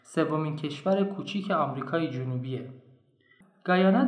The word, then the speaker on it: Guyana: در فارسی می نویسن گویان در انگلیسی میگن گاینا یا اسم رسمیش Cooperative Republic of Guyana خیلی فکر کردم که بگم گویان یا بگم گایان یا بگم گاینا تصمیم گرفتم اونجوری که مردم خود کشور تلفظ میکنن بگم چون منطقی تره و خودمونم دوست داریم مردم دنیا کشورمون رو همونجوری که ما میگیم تلفظ کنن برای همین از این به بعد میگیم گایانا